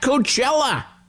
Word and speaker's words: Coachella: I'm at Coachella, which rhymes with hella.